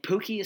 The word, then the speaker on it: Pukki: And Pukki is spelled P-U-K-K-I, but it's pronounced like, oh, hey, Pukki, I love you.